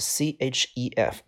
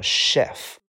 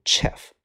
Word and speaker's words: chef: 好，下边一个单词的意思叫做呃，这个单词 c h e f，这个单词的正确读音叫做 chef，不读 chef，读 chef，它的意思叫做大厨，就是在一个餐厅的厨房里边那个呃嗯管理所有人的那个人叫大厨，他不光管做饭啊，他去管理啊订菜啊，然后管理工作呀，都是这个词叫做 chef，chef。 chef 啊，